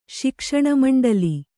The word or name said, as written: ಶಿಕ್ಷಣಮಂಡಲಿ